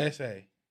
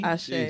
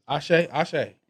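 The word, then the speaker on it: Àṣẹ: Essay. How you say it? Asay? Asay? – Ashay. – Jesus Christ. – Ashay.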